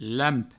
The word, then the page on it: lamp: Provençal